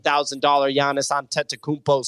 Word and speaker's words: Giannis Antetokounmpo: And in January, there was $25 million of sales volume, already over $6 million of volume in February, led by that $190,000 Giannis Antetokounmpo sale.